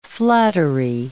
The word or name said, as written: flattery